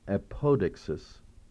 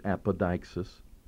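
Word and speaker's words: apodeixis: Now, the Greek word here is apodixis, or you could pronounce it apodixis, of the Holy Spirit.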